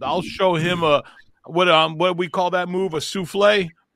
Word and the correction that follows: suplex: Suplex. – Suplex. – The X is, yeah, in wrestling, the X is pronounced.